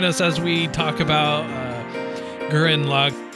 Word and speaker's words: Gurren Lagann: And my pick to discuss is Aaron Logan. I said that wrong. I said that very wrong. I said that so wrong. Give me a moment, sir. – Oh, God. – You're a dick. Crap. How do you say it? – Do you mean Gurren Lagann? – Yes, that's...